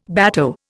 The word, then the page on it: bateau: Indian